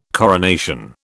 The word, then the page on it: coronation: British English